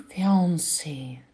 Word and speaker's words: fiancé: И жених fiancé, на второй слог падает ударение. Также невеста fiancé, то же самое.